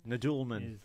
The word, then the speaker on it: Nadelman: We were talking earlier about one of the anecdotes that Landis talked about in this, and I know I'm going to say her name wrong, but Deborah Nadelman? – Nadelman.